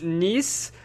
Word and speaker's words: Nice: So I know it's pronounced Nice, but